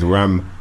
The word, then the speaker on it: Ramson: They always say Ramson and it's Ramson. – It's Ramson. Son, innit? – Ramson. – R-A-M-S-O-N.